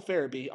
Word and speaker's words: Farabee: joel Faber, Far- Farabee I apologize that I said it wrong. It's Joel Farabee on a six year, $30 million deal.